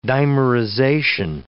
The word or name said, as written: dimerization